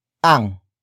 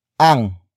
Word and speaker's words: ang: Up next we have ang. Ang. Ang. Ang is spelled as A-N-G.